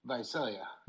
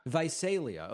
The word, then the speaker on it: Visalia: Let's go next, is it pronounced Visalia? Visalia? How do you pronounce it? – Visalia. – Visalia. Okay, good.